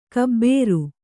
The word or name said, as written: ಘಾಬರಿ